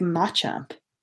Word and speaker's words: Machamp: A lot of people like Machamp.